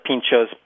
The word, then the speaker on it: pintxos: Right, and we can spell it Pinchos, P-I-N-T-X-O-S, Pinchos.